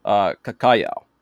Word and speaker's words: Cacajao: U-A-K-A-R-I-S. A common name for monkeys of the genus... I'm going to pronounce this one. Try and pronounce this one with my Portuguese roots. Cacajao. C-A-C-A-J-A-O.